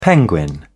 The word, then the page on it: penguin: British English